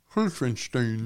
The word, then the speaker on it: Helfenstein: Now the secret of pronouncing German is to pronounce it further back in your mouth than you think you should. – Helfenstein.